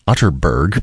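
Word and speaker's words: Atterberg: It's spelled Otterberg, but pronounced Otterberg. I have it on good authority.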